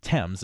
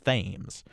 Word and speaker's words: Thames: It seems like there are a couple of ideas about why it's pronounced that way, Thames instead of Thames. The first says it actually was Thames, but King George I, who apparently had a thick German accent, had trouble pronouncing the TH sound at the beginning, and so he ended up pronouncing it Thames.